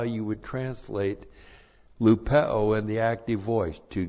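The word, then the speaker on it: Lupeo (λυπέω): And then the verb lupeo, and this is used also over and over in this passage. Lupeo, L-U-P-E, long O, lupeo.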